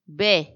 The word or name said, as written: be